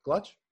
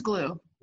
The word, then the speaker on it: Gluch: Gluch? – Glue. It's German, like Elmer's glue. – Glue. Oh, silent C-H, huh? – Yeah, C-H is silent.